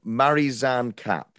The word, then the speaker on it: Marizanne Kapp: But it was now I never say her name correctly, but it's Marizanne Kapp.